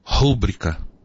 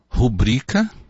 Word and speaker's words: rubrica: Nobel, rúbrica, austero, púdico, gratuito, recorde, ínterim, catéter, mister. Percebeu alguma coisa errada? A pronúncia de todas essas palavras está errada. Há erro de prosódia, e erro de prosódia é chamado de silabada. Como seria então a correta pronúncia, a correta acentuação dessas mesmas palavras? Seria assim: Nobel, rubrica, austero, pudico, gratuito, recorde, interim, cateter, mister.